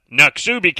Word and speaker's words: Noxubee: It's N-O-X-U-B-E-E. And I said, Noxubee. That is not the pronunciation. I figured it wasn't. Jeff 2 says, I lived in Mississippi for 20 years, and I can confirm that Noxubee County is actually pronounced Noxubee.